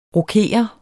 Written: rokere

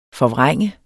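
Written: FORVRÆNG

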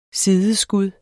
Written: sideskud

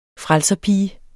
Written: frelserpige

